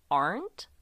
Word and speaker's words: Arndt: So at 8 o'clock a.m., Boulder Detective Linda Arndt, I think that's how you say it, it's spelled A-R-N-D-T.